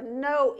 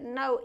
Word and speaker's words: Noe: or Noe is how you pronounce that, Noe, which was the son of Lamech, which was the son of Methuselah, which was the son of Enoch.